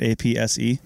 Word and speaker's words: apse: Am I pronouncing it right? APSE? – Yeah. Apse. – Yeah. Yeah. Mm-hmm. – I thought I put the definition. – Not to be mistaken for an asp.